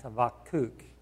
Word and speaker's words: Habakkuk: His name actually is properly pronounced Habakkuk, for anybody that would like to know that.